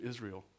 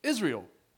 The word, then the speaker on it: Israel: We like to say the name Israel, Israel. But that's not how it's pronounced. That's not how it's pronounced. It's pronounced Israel. So can we practice that real quick? On the count here, let's say it together. One, two, three. Israel. That's how it was pronounced. It was pronounced Israel.